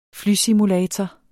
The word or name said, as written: FLYSIMULATOR